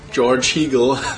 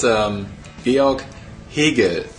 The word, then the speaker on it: Georg Hegel: I know that's difficult for an American to pronounce, but it's Fichte, and it's George Hegel. That's Georg Hegel, and the Volksschule is Volksschule.